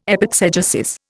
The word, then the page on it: epexegesis: Indian